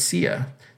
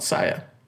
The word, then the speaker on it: Sia: This whole time I'm pronouncing it Sia. It's actually called Saya.